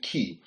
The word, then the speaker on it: kai: Now, that word "and" is the word in the Greek "key," spelled K-A-I. We pronounce it as "kai," but I heard the Greek guys call it "key."